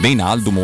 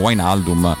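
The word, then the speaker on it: Wijnaldum: Come dice l'amico Alessandro Colantoni, si dice Gini Veinaldum.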